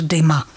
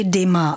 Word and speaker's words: Dumas: To naše základní pravidlo, o kterém jsem mluvila, totiž ta vyslovovaná podoba je komplikovaná například ve francouzštině, takže Alexandr Dima. Píše se Dumas, a měli bychom se tedy držet té výslovnosti Dima, ale to bychom skloňovali podle vzoru předseda.